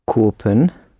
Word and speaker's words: kopen: Ze wil het niet kopen. – Nice long O.